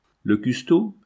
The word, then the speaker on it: custo: Le custo. Le custo, c'est le nom que dans les villages, du fond de la Manche, on donne aux sacristains, et nous l'avons écrit comme on le prononce.